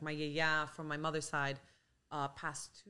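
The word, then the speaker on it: Yaya (γιαγιά): Yaya.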